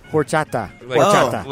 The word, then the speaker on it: horchata: Like, horchata. – Like Cody? – They don't pronounce the H when you really say it.